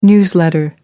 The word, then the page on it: newsletter: American English